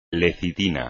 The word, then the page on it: lecitina: espagnol